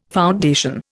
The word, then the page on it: foundation: Indian